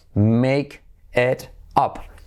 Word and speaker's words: make it up: Fíjate la pronunciación. Make it up. Make it up, make it up. Como me queda bien, me queda bien, me queda mal.